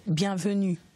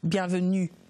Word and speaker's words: bienvenue: So how do I say welcome in Francais? – Bienvenue. – Bienvenue. – Yes. Bienvenue.